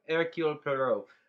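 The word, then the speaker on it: Hercule Poirot: I don't think there's a reason Perot to have care, Hercule, Hercule Perot.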